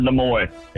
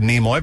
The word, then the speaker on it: Nimoy: It'd be Leonard Nimoy. – It is the great Leonard. I've never heard it pronounced that way. I've always said Nimoy, but I'll take Nimoy.